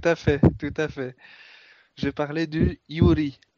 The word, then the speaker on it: Yuri: Parce que, alors, en fait, le Yuri, il faut les prononcer, les R, il faut les prononcer L.